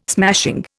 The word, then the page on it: smashing: Indian